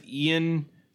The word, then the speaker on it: Iain: Hamlet, by the way, is played by, I think you pronounce it Ian, I-A-I-N, Glenn, who is also in Game of Thrones.